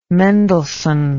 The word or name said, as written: mendelsohn